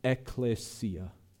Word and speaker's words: ekklesia: The Greek word that we translate church is the word ekklesia. Ekklesia. If you want to spell that, it's E-K-K-L-E-S-I-A.